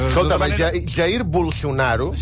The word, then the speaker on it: Bolsonaro: Els que en sabem i els que parlem català... – Efectivament. – Ens acostem a la pronúncia brasilera, que és Bolsonaro...